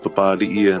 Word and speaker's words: Papalii: it's pronounced Papalii,